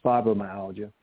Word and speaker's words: fibromyalgia: Yes, it's fibromyalgia.